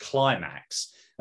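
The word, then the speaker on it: Klymaxx: Have you ever heard of I Miss You by an artist who I think name is pronounced Climax? And that's spelled K-L-Y-M-A-W-S.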